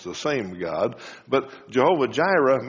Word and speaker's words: Jehovah Jireh: I think I said Rohi before. Jehovah Yireh, Jehovah Jireh, we usually pronounce it.